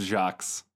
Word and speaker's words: Jacques: Brian Jackies. – I think that's pronounced Jacques.